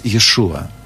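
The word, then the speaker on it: Иешуа (ישוע): Иисус, или в полном еврейском звучании Гошуа, а ко времени, когда жил Иисус на земле, это имя произносилось Иешуа.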